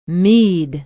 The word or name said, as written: mead